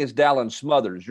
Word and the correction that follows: Smothers: You're saying Smathers? – Dalen Smathers. – All right, I got it all wrong. All right, it's not how it looks like it's spelled to me.